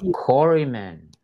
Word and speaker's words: Quarrymen: And then how do you spell that? – Q-U-A-R-R-Y. M-E-N. – Oh, so the Quarrymen. – Quarry. Like the Q-U together make a W.